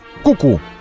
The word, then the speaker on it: QQ: Ну, как Q. Ну, мы, наверное, Ку-Ку должны его читать. Ку-Ку? Ну, пишется как QQ. А мы будем читать его Ку-Ку.